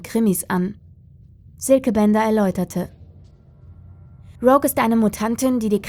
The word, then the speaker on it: Rogue: Camilla und Rogue?« »Es wird Englisch ausgesprochen. Rogue«, korrigierte ihn seine Kollegin.